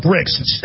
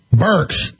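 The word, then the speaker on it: Birx: I always call her Bricks. How do you pronounce it? Burks.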